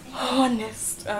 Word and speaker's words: honest: Honest. – See, honest. – The ho is silent.